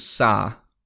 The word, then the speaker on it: sağ: And so there's that silent G, the yumshak ge, silent G, which kind of draws out the pronunciation a little bit, right? – Yes. – Sa. – Sa. – Sa. – You just make the A a bit longer.